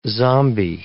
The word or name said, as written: zombi